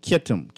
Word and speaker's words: Kittim: The texter was saying that Portugal is called Kitum today, or was called Kitum. – I didn't get that word properly. Kittim? – Kittim. Kittim. Kittim is one of the sons. – Spell it. Because you're not coming over clear. – C-H-I-T-T-E-M. C-H-I-T-T-E-M.